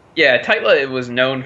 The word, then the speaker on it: Tytla: How do you pronounce that? Tytla. Tytla.